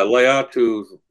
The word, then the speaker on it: Latu: It's is it Latu? Is that how you say it? – Yeah, Latu. Latu.